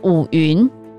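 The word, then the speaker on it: 伍员: 那我的次子小儿子叫做伍云，好，刚刚就有讲过，那个字是员外的员，但是要读作云，就是伍子胥。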